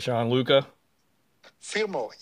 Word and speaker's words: Gianluca Firmo: I tend to slaughter the names of folks that I talk to here, so is that correct, Gianluca? – Firmo, yes.